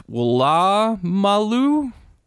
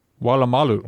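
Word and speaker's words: Woolloomooloo: P.S. Wulu Mulu is pronounced Wala Malu. – Wala Malu.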